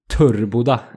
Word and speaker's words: Töreboda: det stavas ju Töreboda. Alltså med Töreboda. Men Törboda är ju liksom det korrekta uttalet.